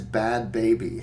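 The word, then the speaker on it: Bhad Bhabie: Her stage name is Bad Baby.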